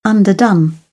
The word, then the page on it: underdone: British English